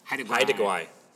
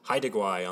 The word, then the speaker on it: Haida Gwaii: Haida Gwaii.